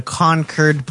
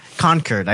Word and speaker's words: Concord: So it says that the Free Staters are, quote, active and present daily challenges, end quote, to the Concord Police Department. – Concord. – Concord. I said Concord. – Concord.